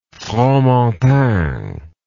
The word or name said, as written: Fromentin